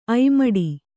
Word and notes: ಐಮಡಿ: aimaḍi

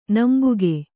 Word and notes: ನಂಬಿಗ: Kannada

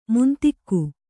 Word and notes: ಮಂಥಿಕ: manthika

